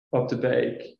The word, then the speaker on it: Opdebeeck: Since exercises like this one invariably result in valiant but usually not very successful attempts to pronounce my surname, Opdebeeck, I thought I would share its meaning.